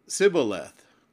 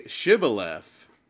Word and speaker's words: Shibboleth: And he said, "Sibboleth." "Say, Shibboleth."